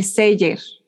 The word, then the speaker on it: Celler: Un poco lo mismo, así, ¿qué me recomendarían para eh, disfrutar en el seller? Tenemos que decir que se dice el Celler.